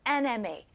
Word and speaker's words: MA: 여기서 발음이 이렇게 M에, E로 발음이 돼가지고, an이 붙입니다. So it's not a MA, but an NMA.